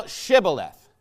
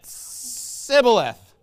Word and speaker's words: Shibboleth: Then said they unto him, 'Say now Shibboleth.' And he said, 'Sibboleth,' for he could not frame to pronounce it right.